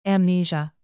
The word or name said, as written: amnesia